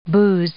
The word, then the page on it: booze: noun